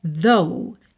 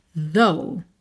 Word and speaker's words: though: Então, olha, though, T-H-O-G-H, a pronúncia é though, though.